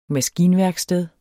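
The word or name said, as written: maskinværksted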